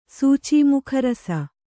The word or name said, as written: ಸೂಚೀಮುಖರಸ